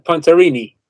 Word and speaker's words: Pontarini: His work on, quote, embodied light, unquote, the book about the Baha'i Temple in Chile by Hari Pontanari. Am I saying that correctly? – Hariri and Pontarini.